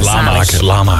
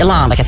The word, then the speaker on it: Lanaken: Jens uit Lanaken, wat een enthousiasme. – Lanaken. – Ah ja, Lanaken. Sorry. Lanaken. En Olivier uit Liedekerke, zeg ik het goed zo, die stuurt een foto van een thermometer door in de Q-app.